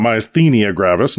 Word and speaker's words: myasthenia gravis: This evening, eventually, we're going to be talking about myasthenia gravis, and I say it like that because, well, that's how it's pronounced.